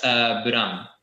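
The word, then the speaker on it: BLAME!: That's Buram. It's spelled blame with like an exclamation point.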